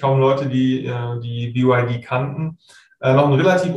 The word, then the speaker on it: BYD: Das ist BYD, so spricht man es amerikanisch auch aus.